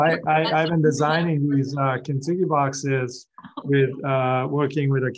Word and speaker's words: Kintsugi: And I apologize if I don't say this right, but is it Kintsugi? – Kintsugi, yes.